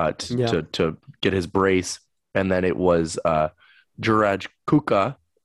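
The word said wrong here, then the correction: Kučka: if I pronounced that correctly. – Kuchka, I believe goal.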